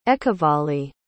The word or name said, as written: Ekavali